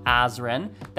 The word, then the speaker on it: Azren: It is at polyglot azren.